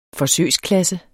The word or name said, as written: forsøgsklasse